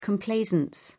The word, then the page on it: complaisance: British English